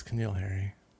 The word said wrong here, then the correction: N'Keal Harry: Well, that's what you said, but yeah, it's N'Keal Harry.